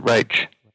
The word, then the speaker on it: Roche: But my last name is actually an Irish name, which is why we pronounce it Roche.